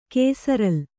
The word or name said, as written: ಕೇಸರಲ್